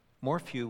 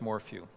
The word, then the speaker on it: Morphew: Morphew would be, I think, a great way to do that. Again, this one is called How Do We Know Christianity is Really True by Chris Morphew. Let me spell that. M-O-R-P-H-E-W.